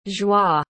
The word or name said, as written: joie